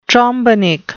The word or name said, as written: trombenik